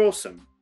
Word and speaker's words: awesome: The pronunciation is completely different to how we write it. So if you look at awesome, A W E S O M E, Awesome. We pronounce it awesome.